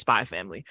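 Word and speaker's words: Spy × Family: It's spelled Spy X Family, but the X is silent. It's just I think you just pronounce it Spy Family.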